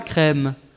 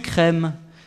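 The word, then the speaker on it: chrême: C'est pas de la crème, c'est du crème. Ça s'écrit C-H-R-E, accent circonflexe, M-E.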